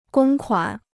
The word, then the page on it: 公款: gōng kuǎn